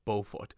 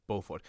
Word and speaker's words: Beaufort: So it is Beaufort, west from the French, she says in her caption. So I've retweeted that as well. So if you want to go and listen to it, it is pronounced Beaufort.